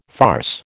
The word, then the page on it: farce: American English